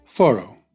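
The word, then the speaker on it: Fårö: Όλα ξεκινούν και τελειώνουν σε ένα σουηδικό νησί, το περίφημο Φάρο, που οι Σουηδοί προφέρουν πιο κοντά στο Φόρο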